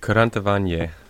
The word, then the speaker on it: Kurentovanje: It's spelled K-U-R-E-N-T-O-V-A-N-J-E. – Kurantavanje.